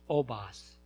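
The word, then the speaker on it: Les Basses: It's L-E-S-B-A-S-S-E-S, pronounced Obass.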